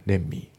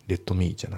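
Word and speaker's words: let me: で、あと発音ですけど、レッドの T ができますね。レンミー。レッドミーじゃなくてレンミー。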